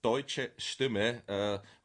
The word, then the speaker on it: Deutsche Stimme: It's Deutsche Stimme, Voice of Germany translated.